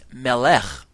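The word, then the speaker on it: melech (מלך): The Hebrew word for king is melech, okay? And this has a similar sound to our word for messenger, which is malach. You hear the two? Melech, which is spelled M-E-L-E-K-H, and Malach, which is M-A-L-A-K-H.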